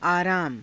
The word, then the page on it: aaraama: Hindi